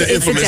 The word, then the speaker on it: infamous: Oh, I guess I'm putting the infamous on a different emphasis. – It's Amanda. – It's infamous. You're putting the infamous on the wrong syllable.